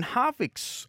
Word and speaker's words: Harvick: Just wondering if you saw Kevin Harvick's. Harvick's? Is that how you say it? – Yep. Harvick.